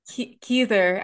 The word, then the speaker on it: Heather: And he's like, no, it's pronounced Heather, like Heath. – Keither, as I would say.